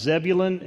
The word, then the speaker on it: Zebulun: The two regions that are mentioned are pronounced Zebulun and Naphtali.